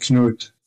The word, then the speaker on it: Knud: It's really hard, English speaking, to give that soft space, to talk about that soft D. It's really difficult. It's not that easy to learn, so I'm perfectly good with Knut. – It's spelled K-N-U-D. Subtle at the end there, right.